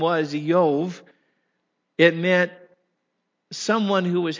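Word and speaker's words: Iyov: The Hebrew pronunciation of his name is Eyov. We've always, growing up in church, just called him Job.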